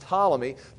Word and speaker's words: Ptolemy: Then Ptolemy, the general, is going to take this one right here, and this is going to be Ptolemy, and it starts with a P, but it's pronounced Ptolemy, Ptolemy's empire.